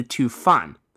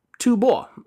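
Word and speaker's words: Tu Bo (吐蕃): However, friend of the show, Yuan Leo, pointed out to me a while back that the word in question, the one I'd been reading as Fan, can actually be pronounced as Bo, and in the case of the Tibetan kingdom, ought to. Tibet, Tu Bo.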